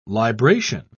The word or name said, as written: libration